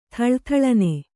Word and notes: ಥಳಥಳನೆ: kannada

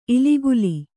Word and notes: ಇಲಿಗುಲಿ: kannada

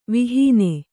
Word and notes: ವಿಹೀನೆ: vihīne

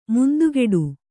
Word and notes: ಮುಂದುಗಡೆ: mundugaḍe

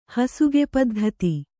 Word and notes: ಹಸುಗೆಪದ್ಧತಿ: hasug paddhati

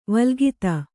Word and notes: ವಲ್ಗಿತ: Kannada

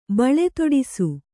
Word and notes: ಬಳೆದೊಡಿಸು: baḷedoḍisu

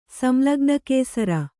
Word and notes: ಸಂಲಗ್ನಕೇಸರಿ: samlagna kēari

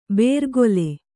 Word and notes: ಬೇರ್ಗೊಲೆ: bērgole

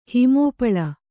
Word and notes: ಹಿಮೋಪಳ: Kannada

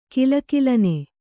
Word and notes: ಘಲಘಲನೆ: ghala ghalane

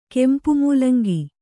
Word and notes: ಕೆಂಪುಮೂಲಂಗಿ: kempu mūlaŋgi

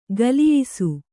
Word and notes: ಖುಲಾಯಿಸು: khulāyisu

